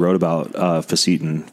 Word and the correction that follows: fisetin: but how do you pronounce it? Fecetin? – Fezetin.